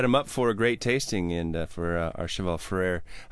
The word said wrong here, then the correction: Achaval Ferrer: How do you say it? – Achaval Ferrer. – Achaval. I got to say that.